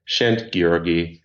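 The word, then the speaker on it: Szent-Györgyi: His name is Albert Szentgyörgyi, and he's, I think I pronounced that okay-ish.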